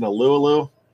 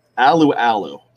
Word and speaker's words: Alualu: But actually, I said that wrong. Alualu. Dave Schofield's always on me for that, and he's right. It's Alualu. I mess that up all the time.